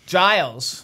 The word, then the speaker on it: Giles: Giles.